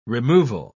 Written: removal

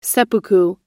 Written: seppuku

